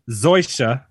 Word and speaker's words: Zoysia: This course boasts Zoya, or how do you say that? Zoya? How do you say that grass? – Does it matter? You gonna say shit anyway. – It don't matter. – Zoysia fairways and bent grass greens.